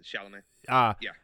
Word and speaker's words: Chalamet: But you know, I think Timothy Chalamet, is that how you say it? – Chalamet.